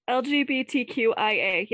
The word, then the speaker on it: LGBTQIA: LGBTQIA, yes.